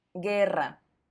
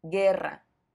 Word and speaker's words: guerra: ya que se pronuncia ge, pero se escribe g, u, e. Por ejemplo, guerra, guerra.